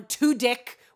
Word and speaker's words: Tudyk: So there's a Cassian Andor show with Diego Luna and Alan Tudyk, which is how you pronounce his fucking name. He said it on Twitter.